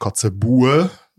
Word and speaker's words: Kotzebue: Also er schreibt sich Kotzebue. Ich würde ihn jetzt Kotzebu aussprechen.